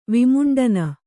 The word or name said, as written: ವಿಮುಂಡನ